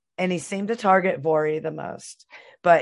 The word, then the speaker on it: Voirrey: The Irving family consisted of James, the father, Margaret, the mom, and I looked this up and Googled it and listened to the pronunciation so I wouldn't mess it up, Vori, the daughter. It's spelled V-O-I-R-R-E-Y. So I was like, that's a really weird name.